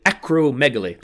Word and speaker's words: acromegaly: great line of acromegaly acromegaly the accent is on the meg like uh you Beautiful.